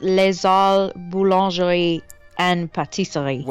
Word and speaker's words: Les Halles Boulangerie and Pâtisserie: Against Le Ha Boulangerie and Pâtissière. – I think I might butcher it too. I think it's Les Halles Boulangerie and Pâtisserie.